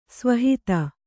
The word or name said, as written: ಸ್ವಹಿತ